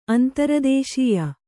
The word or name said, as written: ಅಂತರದೇಶೀಯ